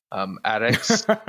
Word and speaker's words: arak: And he's actually, his first topic was Iraqs. So, or how he's, I'm trying to think of how he says it, because he says it obviously correctly. Addicts is how it's actually pronounced.